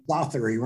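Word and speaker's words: misothery: Misotery? – Misotery rhymes with misogyny.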